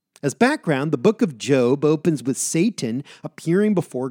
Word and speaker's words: Job: J-O-B is pronounced Job.